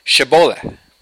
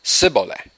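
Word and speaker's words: Shibboleth: and he would ask him to pronounce the word Sheboleth, which apparently the people from Ephraim would mispronounce it Sibole, and at which point they would be killed on the spot.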